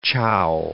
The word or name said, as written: chow